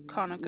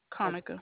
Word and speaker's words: Konica: Konica. – Monica? – Konica. – Konica. How you spell it? – K-O-N-I-C-A. – K-O-N-I-C-A. All right.